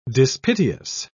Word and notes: dispiteous: British English

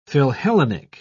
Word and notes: philhellenic: British English

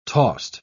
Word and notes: tost: British English

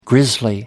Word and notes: grizzly bear: American English

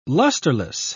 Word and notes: lusterless: British English

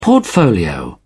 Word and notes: portfolio: British English

